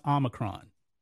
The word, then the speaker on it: Omicron: He calls it Omicron. It's pronounced Omicron. He should learn his Greek letters.